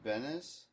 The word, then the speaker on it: Benes: Christopher Priest, Ed Benes. I pronounce it like Elaine Benes, of course.